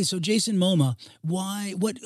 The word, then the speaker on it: Momoa: So let's just say a guy like Jason, by the way, it's Momoa. It's not the Museum of Modern Art. It's not MoMA.